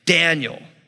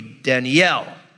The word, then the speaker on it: Daniel: Like my name, you say Daniel. In Hebrew, it would be Danielle.